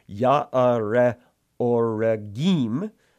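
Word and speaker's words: Jaare-oregim: Verse 19, there was again war with the Philistines at Gob, or Gath, and Elhanan, the son of Jaaret Gorim, I butchered that, sorry, Jaaret Oregim, the Bethlehemite, struck down, it's the brother of Goliath the Gittite.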